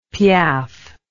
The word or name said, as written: piaffe